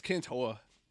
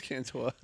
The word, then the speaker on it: Cantore: I'm Jim Cantone. – It's Cantore. – Is it Cantore? – It is Cantore.